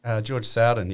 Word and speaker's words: George Sowden: George Sowden, yeah.